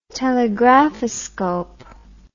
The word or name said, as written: telegraphoscope